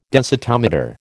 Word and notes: densitometer: American English

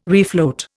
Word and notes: refloat: Indian

